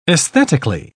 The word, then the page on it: aesthetically: British English